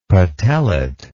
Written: patellate

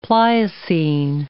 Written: Pliocene

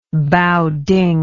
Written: baoding

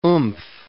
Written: oomph

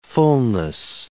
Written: fullness

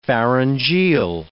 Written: pharyngeal

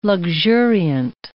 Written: luxuriant

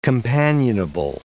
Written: companionable

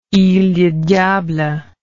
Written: ile du diable

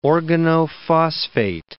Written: organophosphate

